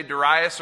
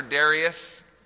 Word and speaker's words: Darius: Xerxes' father, Darius, you can say Darius or Darius, it's pronounced both ways.